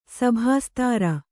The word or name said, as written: ಸಭಾಸ್ತಾರ